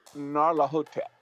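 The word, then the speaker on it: Nyarlathotep: So I always, Gnarlethotep. – Okay. – Like, Bubba Hotep.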